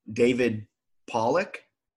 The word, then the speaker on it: David Pollack: I also think it was David Pollack.